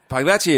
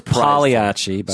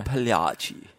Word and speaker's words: Pagliacci: Pagliacci is absolutely amazing. I'm pronounced Pagliacci. – Sorry, Pagliacci.